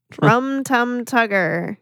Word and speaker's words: Rum Tum Tugger: How do you say, what is it? – Rum Tum Tugger. – Yeah, that's it.